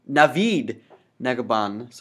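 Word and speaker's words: Navid Negahban: Before we proceed any further, Chris Hemsworth, Michael Shannon, Michael Pena, David Nagaban. Sorry, David, I mispronounced. Navid Nagaban. Sorry, I mispronounced that.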